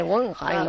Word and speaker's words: Guggenheim: Google, Google, Google, Y le decía yo, Guggenheim. Claro, no se dice así, se dice Guggenheim.